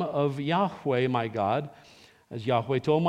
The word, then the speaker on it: Yahweh: God's name is Yahweh. It is not Jehovah. There is no J or V in the Hebrew alphabet.